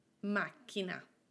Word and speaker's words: macchina: The accent is not on the syllable before the last one. It is on the very first syllable, mac, mac, macchina.